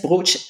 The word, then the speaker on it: brocciu: So the brooch, B-R-O-C-C-I-U, but it's pronounced brooch, it's just a cheesecake made with eggs.